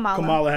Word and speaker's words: Kamala: Kamala Harris. – Kamala Harris. Okay, got it. Like, you know, we black, so yeah. I just want to call a bitch Cam. Like, Cam. – Kamala.